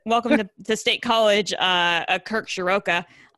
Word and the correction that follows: Ciarrocca: Um, Shiraka, sorry, um, I still have to learn how to say his name, Kirk Ciarrocca, who was the former offensive coordinator at Minnesota.